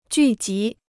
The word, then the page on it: 聚集: jù jí